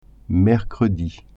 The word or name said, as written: mercredi